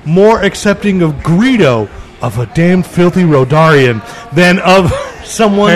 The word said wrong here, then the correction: Rodian: It's Rodian. – Rodian. I'm sorry. Who's it? Rodarian. I added a syllable.